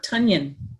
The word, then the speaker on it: Tonyan: I'm going to pronounce his name correctly according to the announcers from last night's game, but it's Robert Tonyan.